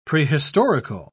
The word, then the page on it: prehistorical: British English